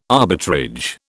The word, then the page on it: arbitrage: British English